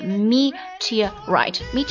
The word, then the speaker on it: meteorite: 再如一颗流星划过天空， A meteorite streaked across the sky，就像流星陨石这个单词读音啊， meteorite M-E-T-E-O-R-I-T-E A meteorite streaked across the sky，他的起跑几乎完美，迅速从那一群人当中窜了出去，还特地去查了一下这个窜的这个是平舌还是卷舌啊。